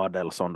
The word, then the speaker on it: Adelson: Adelson, eller Adelson då ifall man vill ha ett svenskt uttal på det.